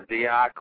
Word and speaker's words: Dyack: It's really hard to pronounce. It's Dyack. – Dyack. – But if anybody butchers it, I will not care. You can say Deke or Dyack, whatever.